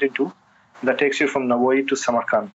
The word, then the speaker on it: Navoi: The way it works is somehow there's a taxi service that only goes to a town that's about halfway, pronounced Navoi, who it's a city named after one of their poets, philosophers, the Soviet era city.